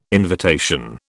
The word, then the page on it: invitation: British English